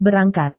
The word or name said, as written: berangkat